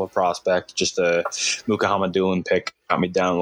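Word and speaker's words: Mukhamadullin: And then Shakir Muka Hamadoulin. Yeah, James pronounced it better than me. – Muka Hamadoulin. – Yes. – Muka Hamadoulin. Muka Hamadoulin.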